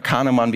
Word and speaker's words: Kahneman: Vielleicht haben Sie Kahneman oder Kahneman, wie er in Deutschland ausgesprochen mal gelesen, langsames, schnelles Denken beschäftigt sich genau mit, mit diesen Abläufen, mit Entscheidungen.